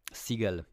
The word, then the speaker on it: Siegel: To nie jest rodzina ze Stevenem Seagalem, to jest Don Seagal. Siegel Pisze się S-I-E-G-E-L. I to wszystko nie jest Seagal.